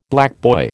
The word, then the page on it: blackboy: American English